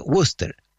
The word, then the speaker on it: Worcester: Se escribe Worcester, pero se pronuncia Worcester, ¿ya?